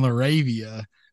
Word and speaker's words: LaRavia: I think that if you give him the same opportunity that we have seen for Lorati and Ravia, Lorati, my goodness, dude. I just butchered the crap out of that. Roddy and LaRavia.